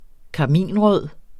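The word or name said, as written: karminrød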